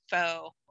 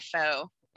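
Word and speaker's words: phở: Pho. I think the right is pho. I was just called pho. – Americans say pho, but I think it's pho. I dated a Vietnamese dude for like a hot second.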